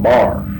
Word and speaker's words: boar: How did she pronounce it? Is it bar? – Well, he pronounced it, he called them bar.